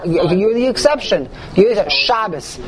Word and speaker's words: Shabbos: But you don't need to come on to that for Shabbos, because Shabbos is the correct way to pronounce Sabbath in Yiddish.